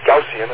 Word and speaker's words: Gaussian: That noise power at 100 megacycles must obey, Wesley used the word Gaussian, he meant Gaussian is the way we usually pronounce it, Gaussian noise distribution.